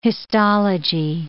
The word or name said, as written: histology